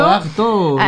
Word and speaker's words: Artaud: Antonin Artaud, non so se si pronuncia così, è scritto Artaud, ma io sono super ignorante in merito. – Artaud, ecco.